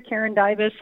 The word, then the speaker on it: Karen Dybis: But also, if they find me on any social media, Karen Dybis,